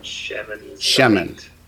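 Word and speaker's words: Shemen (שמן): Shemen.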